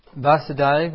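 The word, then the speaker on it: Vāsudeva: Well, yeah, just very briefly, Vasudeva, when we have the stroke above the A, so it's a long A, Vasudeva, that refers to Krishna.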